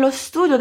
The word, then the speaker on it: lo studio: É por isso que aqui a pronúncia juntou o artigo lo com a palavra studio, transformando essas duas palavras em quase uma só. Lo studio.